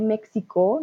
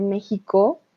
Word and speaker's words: México: La X, por ejemplo, en México no se dice México, no, se dice México como una J.